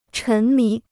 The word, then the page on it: 沉迷: chén mí